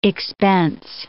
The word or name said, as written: expense